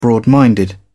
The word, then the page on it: broad minded: British English